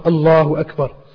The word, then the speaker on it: Allahu Akbar: Similarly, it's not Allahu Akbar. It's lam is full mouth. Allahu Akbar.